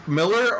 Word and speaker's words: Millar: In Scotland, it's Miller. Over here, we don't really make a distinction if it's spelled E-R or A-R at the end. So you've got Frank Miller and Mark Millar. Over in Scotland, everybody's just Miller. – Gotcha. – It's just one pronunciation.